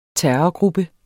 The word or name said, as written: TERRORGRUPPE